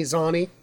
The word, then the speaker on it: Zani: His name is Tony Zani, and it's spelled like Zany, Z-A-N-I.